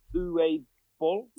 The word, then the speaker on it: Uwe Boll: Uwe Boll.